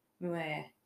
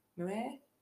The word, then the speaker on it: Muir: I don't know if I'm pronouncing that right. M-U-I-R. John Muir. Muir?